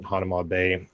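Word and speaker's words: Hanauma Bay: First morning there, we go to the beach, Hanuma Bay. Some people pronounce it Hanama Bay.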